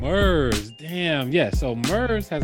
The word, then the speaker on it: Murs: You know Moors, Mures, MURS. – Mers? – Mers. Oh, Mers.